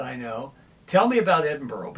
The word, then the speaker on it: Edinburgh: Well, Edinburgh, Scotland, which pronounced Edinburgh, by yes. – the way, by the locals.